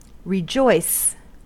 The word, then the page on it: rejoice: American English